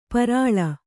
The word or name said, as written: ಪೇರಲ